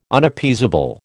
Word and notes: Unappeasable: American English

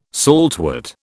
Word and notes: saltwort: British English

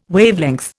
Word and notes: Wavelength: Indian

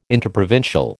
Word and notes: interprovincial: American English

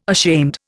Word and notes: ashamed: Indian

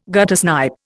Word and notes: guttersnipe: Indian

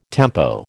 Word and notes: tempo: American English

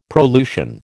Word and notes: prolusion: American English

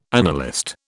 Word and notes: annalist: British English

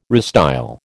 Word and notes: restyle: American English

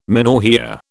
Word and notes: menorrhoea: British English

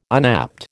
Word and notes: unapt: American English

